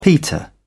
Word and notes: peter out: British English

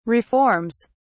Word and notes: reforms: British English

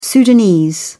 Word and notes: sudanese: British English

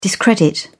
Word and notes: discredit: British English

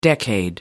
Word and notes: decade: American English

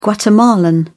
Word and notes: guatemalan: British English

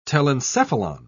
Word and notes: telencephalon: British English

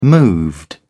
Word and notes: moved: British English

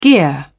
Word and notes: gear: British English

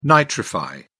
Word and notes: nitrify: British English

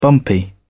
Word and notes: bumpy: British English

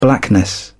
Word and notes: blackness: British English

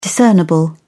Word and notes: discernible: British English

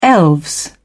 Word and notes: elves: British English

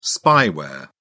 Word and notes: spyware: British English

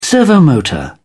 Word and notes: servomotor: British English

